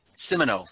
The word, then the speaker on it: Simoneau: Simoneau.